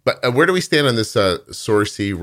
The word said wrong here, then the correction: Saoirse: Ronan girl? We okay with her? – Saoirse Ronan. – Is that how you say it? – Yeah.